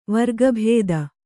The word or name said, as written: ವರ್ಗಭೇದ